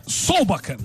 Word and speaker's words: Solbakken: É o Solbak, ou Solbeck, eu não lembro a pronúncia correta dele, acho que é Solbak, né? É isso? – É, Solbakken.